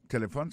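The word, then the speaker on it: telephone: N'a pas remarqué que l'accent tonique l'a tombé sous premier syllabe là. Si nous besoin de dire bien, c'est téléphone. Alors, ou prononcer ou appuyer plus sous premier syllabe là qui c'est T.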